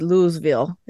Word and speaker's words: Louisville: The only thing I know in Kentucky is Louisville.